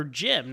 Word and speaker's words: Gem: G-E-M. – It is G-E-M. Okay. – It's G-E-M, Gem. – So would you pronounce it Gim or Jim? No. So Jim it is.